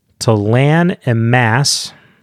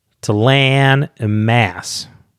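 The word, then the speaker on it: T'lan Imass: And we got Tyst Andy, Kerald Gillane, Jag Hoot, not a silent T, Talan Amass. He reminded me, the Canadians like their flat A's. Talan Amass.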